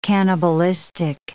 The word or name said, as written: cannibalistic